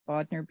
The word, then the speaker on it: Bodnar: J-O-L-A-N-T-A. – Yes. – And my last name, Bodnar, B-O-D-N-A-R, and my okay.